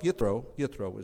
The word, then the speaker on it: Jethro: And Jethro shows up. Yethro is how we pronounce it. There's no J in Hebrew.